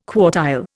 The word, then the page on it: quartile: Indian